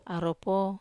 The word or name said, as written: aropo